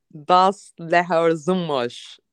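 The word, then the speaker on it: Das Lehrerzimmer: Bir diğer filmimiz şimdi orijinal ismiyle Das Leher Zımmaş diye okunuyor sanırım. The Teacher's Lunch, Öğretmenler Odası.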